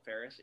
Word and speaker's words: Farris: By the way, my last name, Farris, is spelled F-A-R-R-I-S.